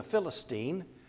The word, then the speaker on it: Philistine: He had smitten the Philistines twice. Now, when you think of David and Goliath, Goliath being a Philistine, or as some pronounce it, a Philistine, I've never quite been able to do that, but I'm getting there.